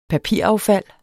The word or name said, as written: papiraffald